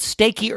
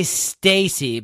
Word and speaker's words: Stacy: I would say Stacy, because the E after the C kind of softens that C.